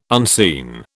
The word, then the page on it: unseen: British English